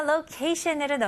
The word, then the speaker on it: location: 다음 단어 location으로 넘어가겠습니다. 네, location은 장소라는 뜻을 잇지만 좀 더 위치에 가까운 단어입니다. 네, 발음 연습부터 해볼게요. Location, location. 오케이, 같이 해볼게요. Location. Shun. 마지막 T-I-O-N 발음은 S-H-U-N으로 한번 해볼게요.